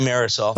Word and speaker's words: thimerosal: A mercury-containing preservative called thimerosal? How do you say that? – Thimerosal.